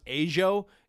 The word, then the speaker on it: Ajo: And then the last place, it's called, I think it's pronounced Ajo. It's A-J-O, Ajo.